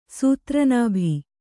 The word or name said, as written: ಸೂತ್ರನಾಭಿ